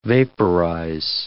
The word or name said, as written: vaporize